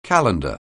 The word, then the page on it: calendar: anglais